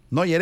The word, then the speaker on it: Neuer: Neuer Epa, así es el apellido, así se pronuncia.